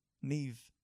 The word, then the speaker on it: Niamh: N-I-A-M-H. – Yep. – Niam? I feel like the H is silent. – Yeah? – Yeah. – You want to know, how it's said? Neve.